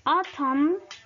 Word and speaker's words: autumn: Silent N, la N silenciosa. Autumn, autumn, otoño.